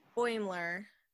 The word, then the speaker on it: Boimler: Boimler. – Like Boiler with an M.